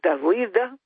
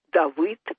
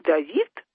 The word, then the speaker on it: Давыд: Вошел сатана в сердца некоторых, в сердце Святополка и Давыда, там именно так читается, Давыд, не Давид, а Давыд.